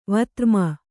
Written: ವಿಧರ್ಮಿ

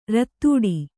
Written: ರತ್ತೂಡಿ

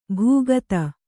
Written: ಭೂಗತ

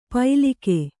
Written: ಪೈಲಿಕೆ

